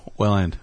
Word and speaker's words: Welland: It rhymes with sand. You have that. – Oh, Welland. Welland. – Welland.